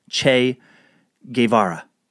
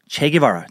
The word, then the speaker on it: Che Guevara: Che Guevara." Che Guevara, and she showed me the pronunciation.